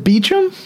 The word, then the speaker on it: Beauchamp: I want to touch a bit on the introduction by Carrie Beauchamp, which, it's spelled Beauchamp, but whenever they announce her, they say Beauchamp. – Yeah. – I always want to say Beauchamp because that's how it's spelled. – Is it Beecham? – That whenever they introduce her. – I think you're right. Yeah, that is what they've said. – Whenever they introduce her, they say Carrie Beecham.